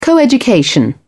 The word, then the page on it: coeducation: British English